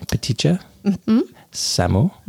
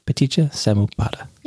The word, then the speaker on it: Paticca-samuppada: Take me through the pronunciation. Paticca-samuppada. – Mm-hmm. Yeah. – Paticca-samuppada. – Yeah. Good job.